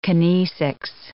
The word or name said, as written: kinesics